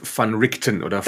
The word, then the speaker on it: Van Richten: Es ist Rudolf van Richten Van Richten.